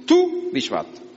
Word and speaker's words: Tu Bishvat: Vav se pronuncia también u. Tu Bishvat.